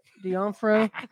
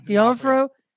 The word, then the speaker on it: D'Onofrio: D'Onofrio. D'Onofrio.